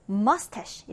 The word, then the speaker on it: mustache: 이때 ch가 sh 발음이 됩니다. 마치 요리사, chef에서처럼. 자, 그래서 같이 발음해 볼게요. mustache. mustache.